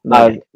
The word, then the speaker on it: Mojang: Like, Minecraft is still on all of the systems, and that's Mojang? I don't know how to pronounce Mojang. – It. Mojang.